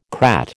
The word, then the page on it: crat: American English